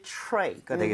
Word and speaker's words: tray: So, 이 쟁반을 뜻하는 것이 tray가 되겠습니다. 발음할 때 당연히 트레이가 아니겠죠? – 네. – TR. 그 다음에 모음이 나오게 되면 치읓 비슷하게 발음이 될 수밖에 없습니다. 왜? R 발음 때문에.